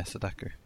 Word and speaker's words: Sadako: Is it how do you pronounce it? – Whose name? – Sadako Yamamura. – Yeah, Sadako. That's it.